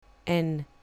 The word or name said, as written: n